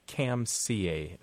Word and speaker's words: Kamsia: and it's transliterated into Roman characters as Kamsia, K-A-M-S-I-A, with a long A.